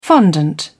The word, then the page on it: fondant: British English